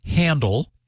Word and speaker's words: HNDL: The security world has coined another abbreviation, which is pronounced HANDL, because the abbreviation is HNDL.